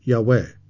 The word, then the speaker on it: Yahweh: Scholars believe the full name of the Lord, the Tetragrammaton, or four letters YHWH, is pronounced Yahweh.